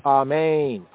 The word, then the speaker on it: amen: The accent in both cases is on the second syllable, amen, amen.